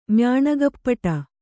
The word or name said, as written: ಮ್ಯಾಣಗಪ್ಪಟ